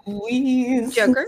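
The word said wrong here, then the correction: Luis Guzmán: Joker, say it correctly. – Luis Guzman.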